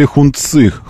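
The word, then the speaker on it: Hongqi: Honchi. Так правильно произносить, кстати, еще раз. Если там всякие мне там вчера тоже писали. Че, мы вот в Китае бывали, там все время называли Хунци. Honchi. Так произносится этот бренд.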